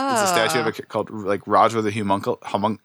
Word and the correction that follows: Homunculus: Is it? How do you pronounce it? – Homunculus.